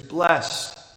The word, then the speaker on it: blessed: And yet the translation, "blessed," is a bit soft, even a little sentimental, especially when we pronounce it with the fanciful accent on the second syllable, "blessed."